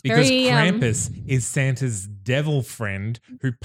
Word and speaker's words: Krampus: Is it Krampus? – Krampus mm-hmm. or Krumpus? – Krumpus? – In America, it's Krampus. Krampus, yeah.